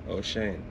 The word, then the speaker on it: Oshane: Ochane. – Ochane? – Yeah. – How do you spell that? – O-S-H-A-N-E.